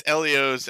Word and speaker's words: Elio's: Is it Elio's or Elio's? – It's Elio's, end of story.